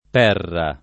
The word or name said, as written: Perra